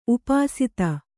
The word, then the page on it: ಉಪಸ್ಥ: Kannada